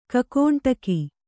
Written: ಗೋಕಂಟಕ

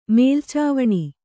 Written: ಮೇಲ್ಚಾವಣಿ

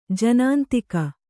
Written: ಜನಾಂತಿಕ

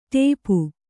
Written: ಟೋಪು

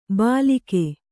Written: ಬಾಲಿಕೆ

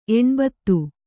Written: ಎಣ್ಭತ್ತು

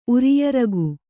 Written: ಉರಿಯರಗು

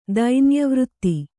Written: ದೈನ್ಯವೃತ್ತಿ

